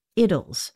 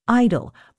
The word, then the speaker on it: idyll: Now, a note on the pronunciation of that word, I-D-Y-L-L. In England, they pronounce that idyll, and generally in America, they pronounce it idle. But I have chosen to pronounce it idyll because of a nifty little four-line poem by Dorothy Parker, commenting on the work of Alfred Lord Tennyson.